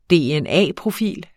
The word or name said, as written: dna-profil